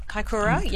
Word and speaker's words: Kaikoura: Kaikoura. I can't pronounce it. Kaikoura. – Kaikoura, yes.